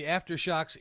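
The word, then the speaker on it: Aftershokz: And you got to wonder, with the next item, the Aftershocks ear, open ear and bone conduction earphones, did I say that correctly? – Yeah.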